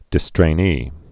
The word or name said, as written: distrainee